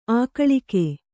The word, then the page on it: ಅಗಲಿಕೆ: kannada